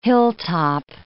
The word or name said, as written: hilltop